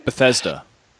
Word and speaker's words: Bethesda: How do you say that in English? – Bethesda. – Yeah, the pools of Bethesda.